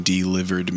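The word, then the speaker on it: deliveree: It's pronounced delivere. It's like divorcee.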